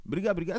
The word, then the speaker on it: Briga Briga: Briga Briga, est-ce que je prononce bien déjà Briga Briga, c'est tout à fait ça.